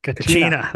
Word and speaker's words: Kachina: How do you say that? – Oh, Kachina. Know. – Kachina, there you go.